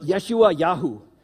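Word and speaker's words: Yeshayahu: Now, Isaiah, the name Isaiah is a Hebrew word, and I'm going to mispronounce it, so don't judge me for this, but Yeshua Yahu, meaning God saves.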